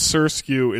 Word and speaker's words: SRSQ: And her name is Surskew. It's spelled S-R-S-Q.